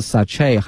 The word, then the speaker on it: sachet: 它的拼写呢是 s-a-c-h-e-t 但是呢在发音的时候这个 t 呢是不能发音的哈它的发音有两个一个是 sachet